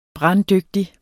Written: branddygtig

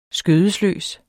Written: skødesløs

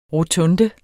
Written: rotunde

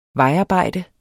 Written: vejarbejde

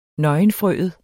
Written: nøgenfrøet

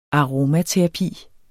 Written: aromaterapi

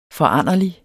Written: foranderlig

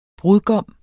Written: brudgom

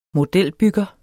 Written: modelbygger

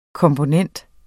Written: komponent

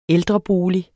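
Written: ÆLDREBOLIG

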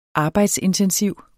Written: arbejdsintensiv

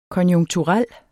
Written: konjunkturel

